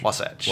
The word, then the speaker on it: Wasatch: Yeah, so it's by, how do you say it, Wasatch? – Wasatch.